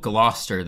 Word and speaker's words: Gloucester: So the band called Gloucester, that is spelled G-L-O. U-C-E-S-T-E-R.